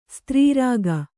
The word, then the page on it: ಸ್ತ್ರೀರಾಗ: kannada